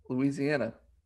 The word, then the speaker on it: Louisiana: How do you say it? – Louisiana. – No, it's Louisiana. – Louisiana.